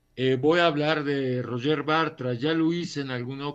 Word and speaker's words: Roger Bartra: con motivo de los 80 años de Roger Bartra o Roger Bartra, como se dice en catalán, como se pronuncia en catalán.